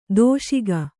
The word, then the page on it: ದಶಕ: daśaka